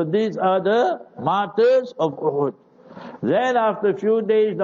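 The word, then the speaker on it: Uhud (أُحُد): Hadith Bukhari Sharif, Jabal Uhudin is Uhud with Dhammil Ha, not Uhud. We must learn to pronounce the names correctly.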